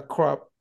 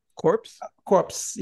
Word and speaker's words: corpse: So then they got into the cave, and they discover a crop. How you say it? – Corpse.